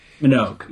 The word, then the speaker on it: Minogue: Minogue.